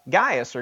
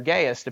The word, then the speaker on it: Gaius: For instance, at the very beginning, he talks about Gaius, or Gaius, depending on how you want to pronounce it.